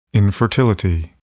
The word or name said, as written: infertility